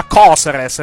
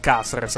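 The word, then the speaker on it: Cáceres: Per lui, per esempio, il giocatore si pronuncia Coseres e non Caseres, per motivi inspiegabili.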